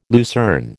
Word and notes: lucerne: American English

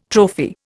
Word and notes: trophy: Indian

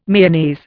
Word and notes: Mayonnaise: Indian

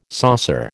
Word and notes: saucer: American English

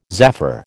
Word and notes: zaffre: American English

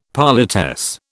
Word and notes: politesse: British English

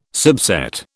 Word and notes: subset: British English

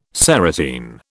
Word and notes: serotine: British English

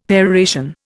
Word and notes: peroration: Indian

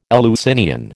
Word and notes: Eleusinian: American English

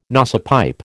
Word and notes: nosepipe: American English

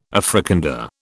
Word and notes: Africander: British English

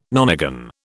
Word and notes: nonagon: British English

